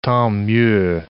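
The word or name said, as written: tant pis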